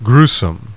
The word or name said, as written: grue